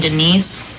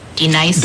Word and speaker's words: Denise: Do you mean Denise? – Son of a... You say your name right now. – Denise? – Say it right. – Denise. – Correctly. – Denise. – Right. – Denise. – Right. – D-Nice. – That's better. Thank you.